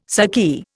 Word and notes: saki: Indian

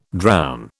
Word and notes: drown: British English

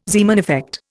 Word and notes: Zeeman effect: Indian

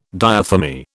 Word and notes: diathermy: British English

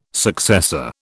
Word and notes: successor: British English

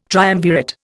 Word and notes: triumvirate: Indian

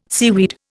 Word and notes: seaweed: Indian

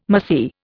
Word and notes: mahseer: Indian

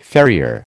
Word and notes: farrier: American English

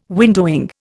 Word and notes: windowing: Indian

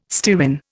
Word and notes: stearin: Indian

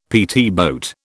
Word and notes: PT boat: British English

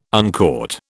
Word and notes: uncaught: British English

